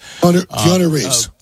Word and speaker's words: Keanu Reeves: Am I saying his name right? Keanu Reeves? – Keanu Reeves.